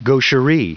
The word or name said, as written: gaucherie